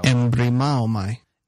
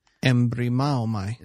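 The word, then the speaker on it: embrimaomai: Okay, you're pronouncing it wrong. And to help us with the pronunciation, we'll listen to the Logos software, give us the right pronunciation of this. Here we go. – Embremaomai. – Embremaomai. That is how you pronounce it? – Embremaomai.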